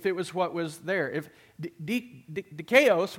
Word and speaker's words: dikaios: And the words that it is represented by today in our study of the New Testament is dikaios.